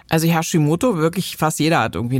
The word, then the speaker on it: Hashimoto: nämlich zum einen den sogenannten Hashimoto.